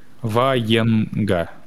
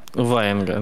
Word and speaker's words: Ваенга: Я не знаю, просто куда ударение ставится. Ваенга. – Ваенга, ну, угу. Так.